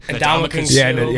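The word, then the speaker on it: Ndamukong Suh: Ndamukong-Suh.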